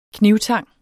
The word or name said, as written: knibtang